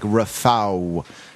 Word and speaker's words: Rafał: I think the stroked L is pronounced kind of like a W, so it's like Rafał.